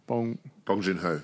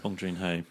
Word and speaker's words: Bong Joon-ho: Because like, I've forgotten how you say his name. – Bong Joon-ho. – Bong Joon-ho. – Bong Joon-ho. – Bong Joon-ho. Think like the month.